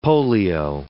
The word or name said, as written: polio